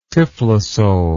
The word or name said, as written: typhlosole